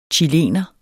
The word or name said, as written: CHILENERE